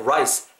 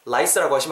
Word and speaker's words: rice: 뒷부분에 I will scoop out the rice인데 일단 the rice 쌀 발음, 밥 발음 말하실 때 rice, rice라고 하시면 안 되는 거 아시죠? rice. 약간 우 발음을 미리 내가 하는데 소리만 안 낸다고 생각을 하세요. 그럼 입술 이렇게 뽀뽀하듯이 둥글게 말린 상태에 시작하겠죠?